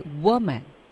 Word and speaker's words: woman: 所以针对单数的，它的发音是 wo，是一个 a 的音，woman。